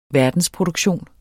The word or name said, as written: verdensproduktion